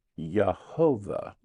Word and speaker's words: Yehovah: Well, some say it's a two-syllable name. It's pronounced Yahweh mm-hmm. or Yahweh. – Mm-hmm. – And then some say, no, it's a three-syllable pronunciation. It's Yehovah.